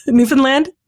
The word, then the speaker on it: Newfoundland: By the way, did I pronounce that right? Newfoundland? – That's pretty good.